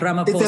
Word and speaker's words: Ramaphosa: It's like not a PH. You don't say the H, so think about it like Ramaphosa.